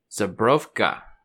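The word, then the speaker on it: Żubrówka: Zabrowka, that's how you say it. It's Polish.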